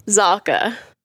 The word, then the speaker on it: Zahka: So, last name, Zahka or Zahka? – Zahka.